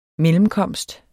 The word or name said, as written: mellemkomst